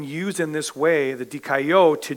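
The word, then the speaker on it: dikaio (δικαιόω): It's one, two, three, dikaio. The accent's on the third syllable.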